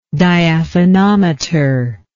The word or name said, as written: diaphanometry